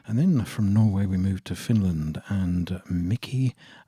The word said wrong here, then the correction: Mika: and Lefty, Mika, I beg your pardon, I always pronounce his name wrong, Mika and Lefty, featuring The Chef.